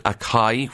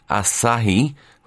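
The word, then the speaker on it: açaí: And then we have in the top 10, that word, I called it acai, which is a fruit used in energy drinks, but according to the Times, it's pronounced as asahi, which I would never have thought of.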